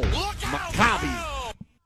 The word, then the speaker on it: Maccabi: How do you pronounce the team they played? And there's a way you can send an audio clip in a text. I get an audio clip back from, he said, pow! Maccabi.